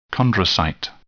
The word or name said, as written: chondrocyte